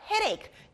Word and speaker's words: Headache: Headache. 여기는 끝에 있는 H가 Silent H예요.